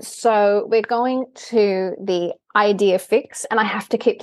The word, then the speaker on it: Idefix: But it's Idefix.